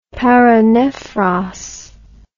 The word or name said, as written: paranephros